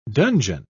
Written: dungeon

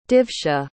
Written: divsha

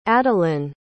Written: adalynn

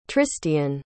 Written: tristian

